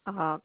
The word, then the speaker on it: Madeline: Madeline, MADELINE.